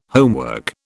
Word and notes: homework: British English

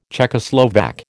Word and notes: Czechoslovak: American English

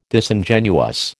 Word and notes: Disingenuous: American English

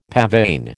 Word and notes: pavane: American English

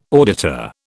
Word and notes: auditor: British English

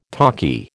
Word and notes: talkie: American English

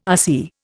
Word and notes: RC: Indian

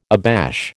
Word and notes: abash: American English

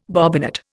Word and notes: bobbinet: Indian

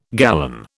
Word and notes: gallon: British English

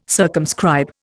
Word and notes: circumscribe: Indian

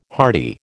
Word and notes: hardy: American English

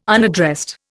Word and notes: unaddressed: Indian

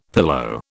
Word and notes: pillow: British English

